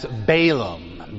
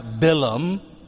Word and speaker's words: Balaam: Most English translations translate him as Balaam, B-A-L-A-A-M. It's pronounced Bilam, and I will probably refer to him as Bilam throughout the evening, but my Bilam is your Balaam, all right?